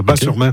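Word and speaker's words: Batz-sur-Mer: Et surtout, la crêperie qui s'appelle le Dervin à Bas-sur-Mer, qui font les meilleures crêpes de la Oui, Bat, on prononce Bas, le T ne se prononce pas, Bas-sur-Mer. Okay.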